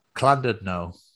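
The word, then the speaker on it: Llandudno: Clandudno. Double L is, for some reason, is a clan.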